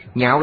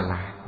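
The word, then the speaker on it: nhạo (樂): Nhạo là vui vẻ. Ở đây chữ này không đọc là lạc, lạc là vui sướng, phải đọc là nhạo.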